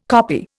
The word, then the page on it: koppie: Indian